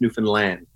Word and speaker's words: Newfoundland: It's Newfoundland.